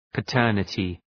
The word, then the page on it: paternity: pə’tɜ:rnətı